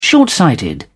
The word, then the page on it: short sighted: British English